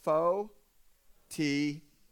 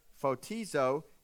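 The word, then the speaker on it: Phō-tī-zō (φωτίζω): We're going to say one word in three syllables. Phō-tī-zō. Phō-tī-zō is the word enlightened, and it means rays of light that lights you up and gets you on fire.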